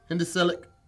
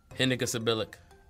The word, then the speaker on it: hendecasyllabic: An apple a day keeps the doctor away is a hendecillic. – Hendecasyllabic.